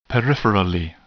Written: peripherally

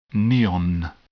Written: neon

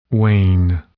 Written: wane

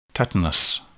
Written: tetanus

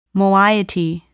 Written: moiety

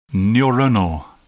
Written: neuronal

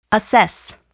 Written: assess

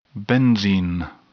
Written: benzene

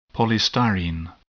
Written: polystyrene

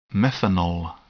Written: methanol

